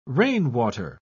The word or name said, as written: rainwater